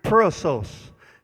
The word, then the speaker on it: perissos: It is pronounced in the Greek perissos. P-E-R-I-S-S-O-S, and it means the sense of beyond.